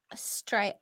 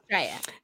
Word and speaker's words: Straya: It is Straya. It's Straya. – It's remember? Supposed to say it like that. Yeah, I know. – Straya.